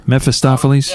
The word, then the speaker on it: Mephistopheles: Mephistopheles in the... did I pronounce that right? – Pretty dang good. I'm going with it. – Mephistopheles? – Mephistopheles, yeah. There you go.